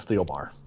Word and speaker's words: Thielbar: Is it Thielbar? Is that how you pronounce his name? – I am reasonably certain it's Thielbar.